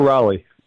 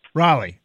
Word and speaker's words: Raleigh: Is it Raleigh or Raleigh? How do you say it? – I go Raleigh. – Say it again? – Raleigh.